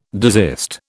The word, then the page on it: desist: British English